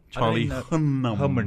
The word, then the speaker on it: Charlie Hunnam: How do you say his name? – Charlie Hunnam.